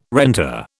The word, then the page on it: renter: British English